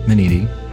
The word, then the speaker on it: Minniti: Dr. Gerald Minniti.